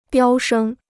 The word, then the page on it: 飙升: biāo shēng